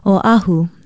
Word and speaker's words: Oahu: Oahu is spelled O-Okina-A-H-U. Although it is often disputed, many say that it means the gathering place. The main thing is to pronounce it correctly, Oahu.